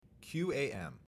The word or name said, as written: QAM